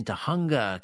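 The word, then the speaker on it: Tujunga: West of the Rockies, Christine is in Tahunga, California.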